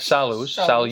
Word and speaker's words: salus: Am I saying it right? Salus. – Yes.